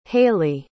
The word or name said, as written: haylie